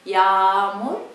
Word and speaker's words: yağmur: Yağmur, yağmur. Normally it's like double A here, yağmur, but it's the natives, we hear the difference.